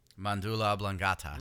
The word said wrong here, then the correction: medulla oblongata: No, it's medulla oblongata. You're saying it wrong too, bitch.